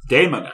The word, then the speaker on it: Daemon: They spent time on Demon Ex Machina. – Daemon Ex Machina. – Daemon. I always pronounce that one as Demon.